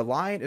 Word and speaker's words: Lyon: The lion is spelled L-Y-O-N. I know it's pronounced like the animal, but get that a lot, so just wanted to throw that out there as well.